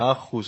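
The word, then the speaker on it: Aarhus: Ε, άκουσα το, ήπιες στην άρφους. Στην ποια? Στην Άχου, στην Δανία. Άχου, εντάξει.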